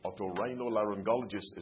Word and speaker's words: otorhinolaryngologist: Otorhinolaryngologist is the long, tongue-twisting name.